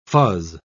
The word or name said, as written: fuzz